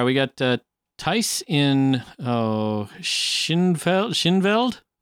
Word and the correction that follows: Thijs: I believe it's pronounced Thijs and Schinveld, Netherlands.